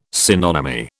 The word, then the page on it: synonymy: British English